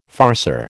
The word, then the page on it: farceur: American English